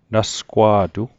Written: nasgwa'tu

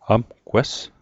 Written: amgwes

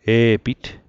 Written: e'pit

